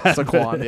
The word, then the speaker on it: Saquon: I've never heard it like that. – Saquon. Yeah. – It's Saquon. All right. And so between Saquon Barkley, Aaron Jones, Javonte Williams.